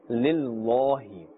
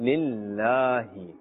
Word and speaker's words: Lillahi: Because that lam of Ism Jalalat, of this blessed name of Allah Azza wa Jal, has a kasra, therefore we are pronouncing it with a flatter sound. We are not saying Lillahi. This is incorrect actually. We are saying Lillahi. A flat sound.